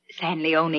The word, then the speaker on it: San Leone: Rancho San Leon. – It's pronounced San Leone. – It ain't written that way.